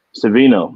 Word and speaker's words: Savino: I've also heard possibly UCLA assistant Darren, see how you say his name? – Savino. – Yep, Darren Savino, possibly.